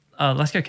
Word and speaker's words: Lascaux: L-A-S-C-A-U-X.